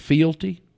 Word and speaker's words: fealty: Have you ever heard of the oath of fealty? – No. – Okay, well, you may want to look it up. Oath, O-A-T-H, just like of fealty, and it's spelled F-E-A-L-T-Y, fealty.